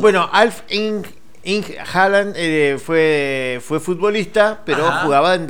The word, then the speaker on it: Alf Inge Haaland: No, pero es así, Alf como Alf. – Ajá, de la sí, serie. igual. – Inge se escribe. – Ajá. – Inge Haaland. – Haaland. Ajá. – Ahora decimos todo junto. Alf Inge Haaland.